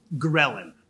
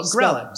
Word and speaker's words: ghrelin: Ghrelin. – Spell it. – Ghrelin. – There's a silent H. – Ghrelin. Yeah, the H is silent.